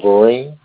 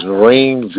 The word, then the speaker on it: Vereen: Reginald Vereen. – All right, how do you spell that? – R-E-G-I-N-A-L-D. Last name is Vereen, V-E-R-E-E-N.